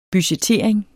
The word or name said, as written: budgettering